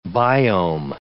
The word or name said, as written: biome